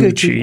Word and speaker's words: Gucci: Oh, Gucci. – Uh-huh. – Is that right, Gucci? – Yep, that's how you pronounce it. – Mm-hmm. The House of Gucci. Gucci, yes.